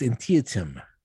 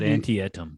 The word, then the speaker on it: Antietam: And I think it's Antietam. Yeah. And I call it anti-etum because that's the way it looks to me.